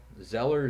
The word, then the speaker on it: Zeller: It's my first name, B-U-C-K. The letter R is in Romeo. Zeller, Z-E-L-L-E-R.